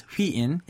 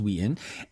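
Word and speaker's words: Wheein: The Korean pronunciation is Hween. English is Ween.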